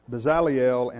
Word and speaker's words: Bezaleel: What I want you to notice is Bezaleel, and that is the way you pronounce it, just for the record, I double-checked, Bezaleel made the Ark of the Covenant.